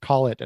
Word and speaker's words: Collett: And I asked you, and you went with Wibolt, and I go over to Blake, whose last name is spelled C-O-L-L-E-T-T. And I, of course, he went with Collett.